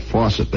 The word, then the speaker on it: Faucett: Fawcett Airlines, F-A-U-C-E-T-T, Fawcett, if you prefer that. Fawcett, they pronounce it there, which was formed by an American who is now dead.